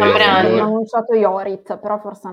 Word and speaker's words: Jorit: Si pronuncia Jorit.